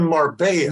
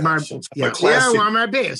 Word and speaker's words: Marbella: And it really goes well with a delicious crunchy bread because, as I said, the sauce Chicken marbella. – Marbella. See, you're pronouncing it the wrong way. I'm pronouncing it the right way. Marbella.